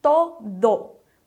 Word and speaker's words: todo: Em todo, que significa tudo ou todo, vamos cuidar para não mudar o ozinho do final e falar todo. A pronúncia é todo. Mais uma vez, todo.